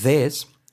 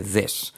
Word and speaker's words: this: Tenemos this, que por cierto, una cosa muy importante es la pronunciación de this. Es una E para empezar, E, this, y luego por otra parte, este sonido, como una Z pero activando las cuerdas, this, y hacer una E, como cuando saludamos, amigo, this, all right?